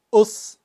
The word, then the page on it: us: Kaqchikel